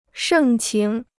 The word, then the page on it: 盛情: shèng qíng